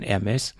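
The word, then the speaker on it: Hermès: Ich, ich spreche mal das H mit aus. Es wird ja gar nicht mit ausgesprochen. Von Hermes. Und es gibt doch Stoffbänder.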